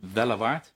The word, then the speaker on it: Wellewaard: We hebben dat ook gezien bij het project Wellewaard. Zeg ik dat goed? Ja.